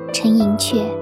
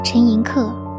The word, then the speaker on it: 陈寅恪: 在秦淮八艳系列里的柳如是那期节目中，我读作陈寅恪，因为汉语大词典里这个字只读作客音。但陈先生是客家人，用客家话的读音就比较接近阙，因此陈先生的家人、亲戚、学生、助手。和文史学界人士都把课念成“ 阙”。